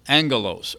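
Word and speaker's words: angelos (ἄγγελος): The Greek in Strong's is number 32, and it's the word agalos, A-G-G-A-L-O-S. You might wonder where we get angels, but that word is actually pronounced angelos or angelos. So the word is spelled A-G-G-A-L-O-S.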